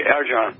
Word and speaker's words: Arjan: Hey, what's up, Arjan or Irjan? How do you say your name? – Just like the way you say it. It's the correct way, Arjan.